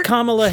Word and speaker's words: Kamala: She's done so little that I forgot the proper way to pronounce her name. It's Kamala. – It's like comma, like a comma.